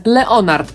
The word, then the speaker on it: Lynyrd: No i właśnie tę pierwszą część wymawiamy tak samo, jak imię Leonard po angielsku, czyli Leonard.